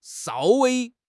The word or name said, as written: shao1 wei1